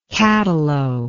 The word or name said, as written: cattalo